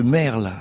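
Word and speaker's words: maërl: Et puis on a nos bancs de merle. Alors là, ça s'écrit M-A-E-R-L. – C'est exact, avec un tréma sur le E.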